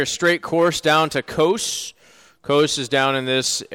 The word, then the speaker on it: Kos: Greek is their long O sound, so we'll pronounce it as that. Kos here again on this.